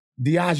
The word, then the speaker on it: Diageo: It's Diageo. – Diageo. Thank you. Okay, so thank you. You let me say Diego for like 10 minutes.